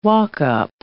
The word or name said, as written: walk-up